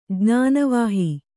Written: ಜ್ಞಾನವಾಹಿ

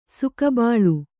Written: ಸೊಗಬಾಳ್

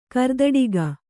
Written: ಕರ್ದಟಕಿ

